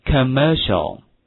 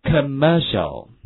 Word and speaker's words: commercial: 广告这个词注意一下发音的话非常的独特，就是有三个 e 的音，跟我读一下 commercial commercial，哎，广告，那外国广告做得好啊，对不对？